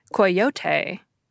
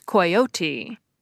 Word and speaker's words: coyote: And some, honoring the Spanish origin or who live near the Mexican border in the United States, might pronounce it the Spanish way, coyote. And the Cambridge Dictionary says the British pronunciation is coyote.